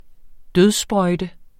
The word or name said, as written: dødssprøjte